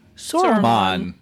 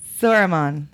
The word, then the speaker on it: Sauron: Sauron. – Sauron.